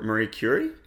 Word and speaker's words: Marie Curie: I don't want to butcher her surname, but Marie Curie, is that how it's pronounced? – Yes, that's right.